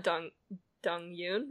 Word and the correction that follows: Dong Hoon: right? Am I saying his name correctly? – Dong Hoon, I think. – Dong Hoon. Okay. Yep, you're right. When you're right, you're right.